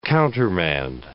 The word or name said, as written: countermand